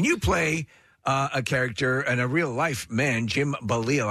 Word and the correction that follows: Balsillie: I hope I'm pronouncing that Basile. – Balsillie. – Balsillie. Okay, can't get that right.